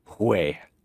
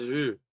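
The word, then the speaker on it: huehue: Oh, that's how it's pronounced? – Yeah, it's huay. – Oh, I thought it was hue. Like, yeah, no, anyway. It's spelled like the English word hue,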